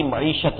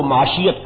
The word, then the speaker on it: معیشت: باقی دو نعرے تھے کہ جمہوریت جو ہے وہ ہماری سیاست ہے اور سوشلزم ہماری معیشت ہے، جسے وہ معاشیت کہا کرتے تھے، اس لیے کہ صحیح تلفظ نے آتا نہیں تھا.